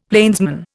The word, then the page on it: plainsman: Indian